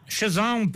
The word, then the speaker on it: Cézanne: Cézanne, Paul Cézanne, très bien. – Qué bien pronunciado, 22.